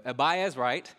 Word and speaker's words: Abiezrite: Gideon was the son of Joash, who was an, I may pronounce this wrong, Abiezrite. There we go, Abiezrite.